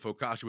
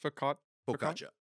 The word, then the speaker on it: focaccia: and then the focaccia would be the cookie to add to but, you know, we're all different, so. – Is that how that's pronounced? – Focaccia. – Focaccia. – Yeah.